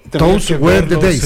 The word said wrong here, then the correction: Those Were the Days: Those Were the Days.